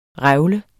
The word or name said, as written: revle